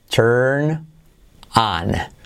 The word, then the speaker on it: turn on: Mira la pronunciación, turn on. la R letra clave que se come la vocal de delante, por lo tanto no hay una U, no es turn, sino turn, turn, ok, turn on.